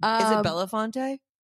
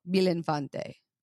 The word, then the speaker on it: Belinfante: Is it Belafonte? – Belin. B-E-L-I-N. – Oh. – Belinfante.